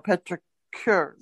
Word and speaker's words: petricure: How's that pronouncing the word again? – Petric. – Let me go back. Petricus? – No, petricure. Petricure. – Like a pedicure.